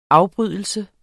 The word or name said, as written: afbrydelse